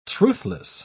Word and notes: truthless: British English

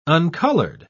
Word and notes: uncolored: British English

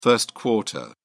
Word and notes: first quarter: British English

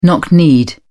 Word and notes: knock kneed: British English

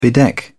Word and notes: bedeck: British English

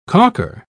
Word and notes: caulker: British English

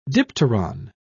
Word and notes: dipteron: British English

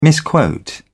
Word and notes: misquote: British English

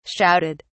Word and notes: shouted: British English

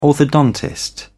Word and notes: orthodontist: British English

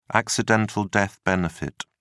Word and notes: accidental death benefit: British English

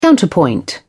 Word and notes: counterpoint: British English

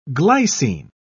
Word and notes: glycine: British English

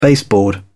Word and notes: baseboard: British English

